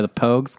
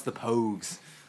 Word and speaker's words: Pogues: I think it's pronounced the Pogues.